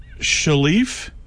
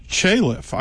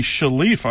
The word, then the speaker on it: Chalif: You pronounce it Shalif? – Shalif. – And for listeners that know their birds of Mexico, he was a co-author with Roger Torrey Peterson on the Houghton Mifflin Birds of Mexico. And I always called it Chalif. I thought it was pronounced Chalif. So this is news to me. Shalif. I've got to change my pronunciation of his name.